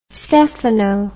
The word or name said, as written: stefano